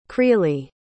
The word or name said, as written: kreeli